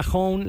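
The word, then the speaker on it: Honne: Si se quieren llevar un boleto doble para ver a Hone,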